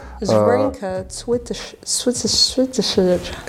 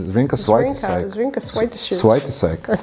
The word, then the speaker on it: Zrinka Cvitešić: A daj pročitaj, daj to izgovori kak nju predstavljaju. – Zrinka Cvitešić. – To puno bolje zvuči. Zrinka Svajtisajk. Uglavnom, šta si htjela reci? – Ona glumi u toj seriji.